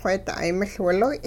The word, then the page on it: wheeda'ay miL-wiloy': Hupa